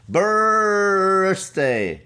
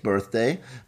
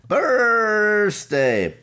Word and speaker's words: Birthday: Und es ist wieder soweit, der Holy, und ich muss es genauso aussprechen, nicht mit TH, sondern mit S, Birthday, nicht Birthday, Birthday.